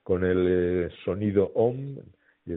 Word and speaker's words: om: Sí, la, la sílaba OM, que en sánscrito es una sola sílaba, en realidad es A-U-M. Y la A no es una A, la U no es una U y la M es una M con una componente de N.